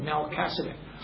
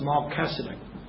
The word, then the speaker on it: Melchizedek: Now we read the Bible, of course, it's written the way it pronounced it, Melchizedek. But it's Melchizedek.